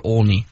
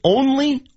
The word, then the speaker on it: Olney: By the way, I want to read you guys this tweet from Buster Olney real quick. – He's not going to let this go. – Is it Olney or Olney? – Olney.